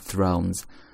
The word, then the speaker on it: Thrones: 首先，其次呢是有一个双元音，o，throne 加 s，z 读 z，thrones，thrones，Game of Thrones，Game of Thrones。